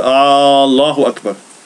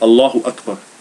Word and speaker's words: Allahu Akbar: Man säger inte Allahu Akbar. Man lägger till en hamza. Fråga hamza. Då man säger Allahu Akbar. Allahu Akbar.